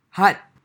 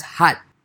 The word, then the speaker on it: khat: So our main topic of today is cut. Cut. No, no, it's cut it's K-H-A-T. But it's pronounced hut. It's pronounced hut. – But there's yes. a K in there.